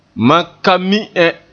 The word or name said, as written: mi